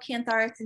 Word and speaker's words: Cantharis: Is that how you pronounce it? I'm never quite sure. – That's it, Cantharis. – Okay. Yeah. She gave me a bottle of Cantharis, and she said, just take some of this through the, whenever you think about it, through the week that you're gone.